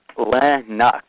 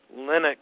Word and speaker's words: Linux: It's Linux, pronounced Linux. Rhymes Linux. – With cynic." – Linux. – How does Linux rhyme with cynic? – With Phoenix? – No, it's spelled C-Y-N-I-C.